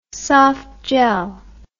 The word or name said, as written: softgel